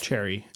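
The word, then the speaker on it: Cherryh: I never remember how to pronounce her name, but C.J. Cherith. – Cherry. It's just Cherry.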